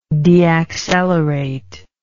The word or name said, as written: deacceleration